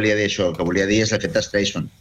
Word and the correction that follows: Streisand: Sí, l'efecte Streisand, sí, però sí, vull dir que...